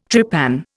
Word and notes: trepan: Indian

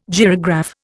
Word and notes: gyrograph: Indian

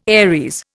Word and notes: Aries: Indian

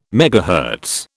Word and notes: megahertz: British English

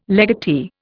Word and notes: legatee: Indian

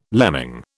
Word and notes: lemming: British English

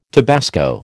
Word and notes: tabasco: American English

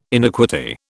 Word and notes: iniquity: British English

